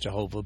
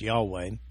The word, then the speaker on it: Yahweh: It's the translation of the personal designation for the God of Israel, the Old Testament saints, translated from the Hebrew word Jehovah, usually pronounced Jehovah, but probably more correctly pronounced Yahweh.